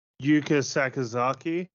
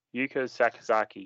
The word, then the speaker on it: Yuka Sakazaki: Yuka Sakazaki. – How did I get that wrong? Yuka Sakazaki.